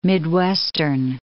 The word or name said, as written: Midwestern